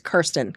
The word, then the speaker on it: Kirsten: I thought it was Kristen, too. It's Kirsten. K-I-R. – Kirsten. – Yep. – But it's spelled. – It's K-I-R.